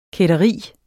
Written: kætteri

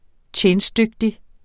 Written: TJENSTDYGTIG

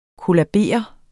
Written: kollabere

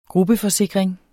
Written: gruppeforsikring

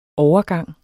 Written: overgang

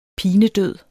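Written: PINEDØD

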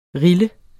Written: rille

